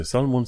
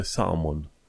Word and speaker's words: salmon: Deci salmon nu se citește salmon, se citește salmon.